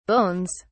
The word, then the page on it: bones: British English